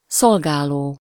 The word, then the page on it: szolgáló: /ˈsolɡaːloː/